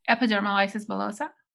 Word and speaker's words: epidermolysis bullosa: Epidermolysis bullosa.